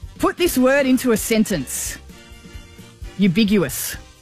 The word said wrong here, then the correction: ubiquitous: Ubiguous or ambiguous? – Ubiquitous. – Oh, hang on, there's a D in it now.